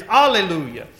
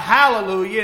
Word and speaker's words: alleluia: In America is where it started being pronounced with an H, and they said hallelujah.